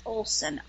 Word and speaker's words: Olson: Okay, well, my first name is Bobby, B-O-B-B-I, and then Jean, J-E-E-N. – Okay. And so last name? – is Olson, O-L-S-O-N.